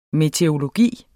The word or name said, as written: meteorologi